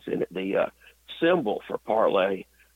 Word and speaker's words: Parler: or it's actually Parlay, I think is the way you're supposed to pronounce it, P-A-R-L-E-R, Parlay, that, instead of Facebook.